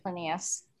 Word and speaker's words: Plinius: I don't even know if I pronounced his name right. Anyone want to take a stab at that? – Plinius. – Pliny. – Plinius. – Plinius. Yeah, I think it's Plinius.